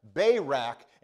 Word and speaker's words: Barak: For the time would fail me to tell of Gideon and Barak, and notice how I pronounce that, Barak and Samson and Jephthah,